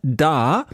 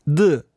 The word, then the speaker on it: Duh: Ich dachte zuerst, er heißt Da, so wie hier. – Da. – Genau, Billie Eilish, ne? Da. Aber der Track heißt The, so muss man es aussprechen. Schreibt sich D-U-H und ist meiner Ansicht nach der beste von dieser 1000 Music EP von DJ Godfather.